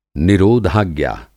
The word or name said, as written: निरोधाज्ञा